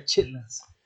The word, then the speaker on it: chitterlings: It's spelled chitterlings officially, but it says chitlins. So everybody I think even the package now is starting to just say chitlins. chitlings. – Should be chitlins.